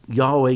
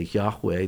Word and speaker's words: Yahweh: There are no vowels in it, so nobody really knows how it was ever pronounced. When I say Yahweh, this is what I was taught in the seminary.